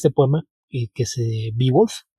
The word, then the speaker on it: Beowulf: Entonces, por eso sé que no, la pronunciación de Beowulf tiene que ser un poquito más extraña. Es de esos casos en los que las vocales las solían ligar. Es Beowulf, pero sí. Es eso de pronunciar exagerado.